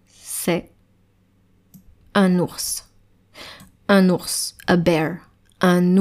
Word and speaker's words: ours: Eh bien parce que il y a un S à la fin du mot ours et on prononce ce S. Normalement, en français, on ne prononce pas le S à la fin d'un mot.